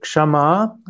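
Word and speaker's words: Kṣamā: Es ist übrigens auch wichtig, dass man ausspricht Kshama, auch wenn ich zugegebenermaßen das häufig falsch ausgesprochen hatte. Kshama ist eben ein weibliches Substantiv und heißt Geduld, Langmut, Vergebung. Kshama mit 2 kurzen A ist ein Adjektiv und das heißt geduldig, ertragend, aushaltend und auch angemessen.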